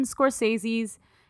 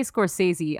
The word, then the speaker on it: Scorsese: How do you say Scorsese? I don't say it right. – No, that's right. – That's right? Okay. – Yeah. I think like if you were in Italy, you'd say Scorsese or something like that. But we say Scorsese here.